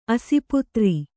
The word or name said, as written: ಆಸ್ಪತ್ರೆ